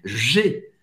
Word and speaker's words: J'ai: J'ai. J'ai. Presta atenção na pronúncia do G G, você vai fazer tipo um sorriso, um sorriso. Olha isso. G, G, G.